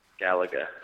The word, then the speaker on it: Galaga: I believe the pronunciation is Galaga. – Galaga. – Yeah! Oh, and we have a real winner. – That is correct.